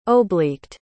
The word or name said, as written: obliqued